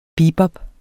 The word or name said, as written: bebop